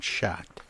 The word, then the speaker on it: Schacht: And breast radiologist at Northwestern's Lynn Sage Breast Center at Prentice Women's Hospital, Dr. David Schacht.